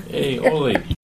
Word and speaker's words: aioli: Aioli. – Aioli.